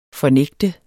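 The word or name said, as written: FORNÆGTEDES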